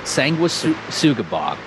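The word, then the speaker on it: Sanguisugabogg: Sanguisugabog.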